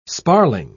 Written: sparling

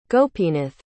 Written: gopinath

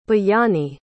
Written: bayani

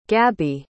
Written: gabi

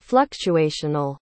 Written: fluctuational

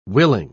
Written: willing

